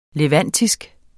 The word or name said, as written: levantisk